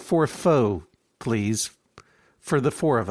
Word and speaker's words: pho: He's like, no, it's pho. Pho.